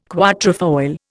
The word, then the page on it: quatrefoil: Indian